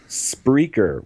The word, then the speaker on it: Spreaker: In the event that you are listening to us on, how do you say it? – Spreaker? – Spreaker.